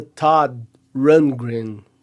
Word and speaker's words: Todd Rundgren: Na verdade é Todd Rundgren. Então fica Todd, você nem pronuncia o D direito.